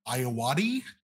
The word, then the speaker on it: Ayoade: Today we are going to be talking about the 2013 movie called The Double, starring Jesse Eisenberg, directed by Richard Ayoade.